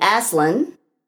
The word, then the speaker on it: Aslan: And the Aslan, reason I want to tell you this is you should definitely look.